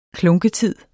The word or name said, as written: klunketid